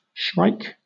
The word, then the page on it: shrike: British English